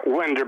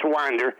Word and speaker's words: Winder: It well, does, it's looks like it's spelled Winder, but it's Winder.